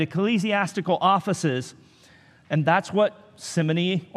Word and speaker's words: simony: The church down through the ages has put a Y on the end of Simon's name, and simony, or simony as it's sometimes pronounced,